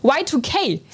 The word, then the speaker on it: Y2K: Und ich habe es, glaube ich, gerade falsch gesagt, es ist Y2K, so heißt es richtig.